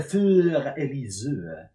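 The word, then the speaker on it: Für Elise: Det är många svenskar och många mycket andra som inte vet hur man uttalar det, men det är Fyr-Elise.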